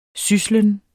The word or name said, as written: syslen